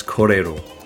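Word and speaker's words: kōrero: That's korero.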